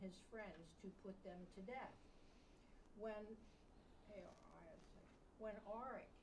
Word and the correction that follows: Arioch: how do you pronounce that? – I think I just called it Arioch. – Arioch,